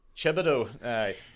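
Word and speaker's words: Thibodeaux: I think it's pronounced Thibodeau.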